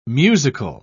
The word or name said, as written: musical